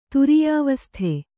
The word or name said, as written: ತುರೀಯಾವಸ್ಥೆ